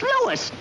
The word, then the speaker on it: Louis: Huh, sounds like there might be a story at the plant, Lois. – Lois? Me name is Lewis, not Lois. Gee whiz, everybody in Toypolate's me name wrong. It's Lewis. L-O-U-I-S.